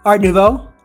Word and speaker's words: Art Nouveau: And then the Rare Variant for only $5 more is going to get you the Art Nouveau. Did I say that right? – Art Nouveau. – Nouveau? I was close.